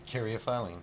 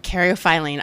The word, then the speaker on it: caryophyllene: Um, Caryophyllene. – Caryophyllene.